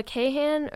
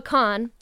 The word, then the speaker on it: Kahan: His name, I thought originally it was like Noah Kahan or like Kahan, but it's actually pronounced Noah Kahn.